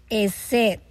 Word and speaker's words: hesed: La palabra misericordia aquí viene del hebreo esed. Y se escribe, ¿verdad?, H-E-S-E-D. Esed.